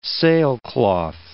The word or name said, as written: sailcloth